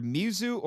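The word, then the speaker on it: Mizu (水): It's pronounced either Mizu or sweet, and it's water.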